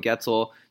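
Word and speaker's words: Goetzel: I'm your host, Noah Getzel. You can follow me on Twitter at Noah_Getzel. Getzel rhymes with pretzel. It's G-O-E-T-Z-E-L.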